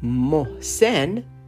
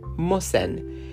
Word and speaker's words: Mohsen: C-O-M-M-E, comme, okay, comme, C-O-M-M-E. Mohsen est mon prénom. Mohsen est mon prénom. Les Français le prononcent comme Mohsen. M comme maman. O H S comme soleil. Soleil is the sun. Soleil. E N M O H S E N. Mohsen. Mohsen. French people drop the H sound. They don't pronounce it. They say Mohsen.